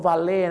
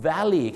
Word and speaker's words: valet: ที่จริงแล้วคำนี้ไม่ได้ออกเสียงว่า valet นะครับคำนี้ก็ออกเสียงว่า valley